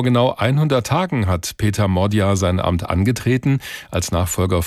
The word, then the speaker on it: Magyar: Er schreibt sich zwar Peter Magyar, also mit Y, spricht sich aber Modyar aus.